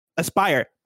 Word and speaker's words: Aspyr: Am I saying that right? Asper? Is it Aspyr? – Aspyr. – That makes more sense. A-S-P-Y-R.